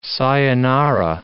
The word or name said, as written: sayonara